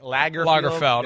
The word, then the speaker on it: Lagerfeld: Karl Lagerfeld, or yeah, something. I'm close, right? – Lagerfeld. Yeah, I think it's Lagerfeld. I don't think it's Field.